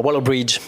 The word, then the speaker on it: Waller-Bridge: notre amie Phoebe en Waller-Bridge, j'espère que la prononciation vous va cette fois. – L'accent est parfait. Ouais.